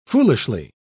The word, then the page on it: foolishly: British English